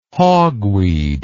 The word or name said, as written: hogweed